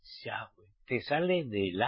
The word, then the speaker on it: Yahweh: Que hay muchos por ahí que no lo pronunciéis bien. Ponéis el acento en la E. Y así termina diciendo Yahweh. Ese no es el nombre.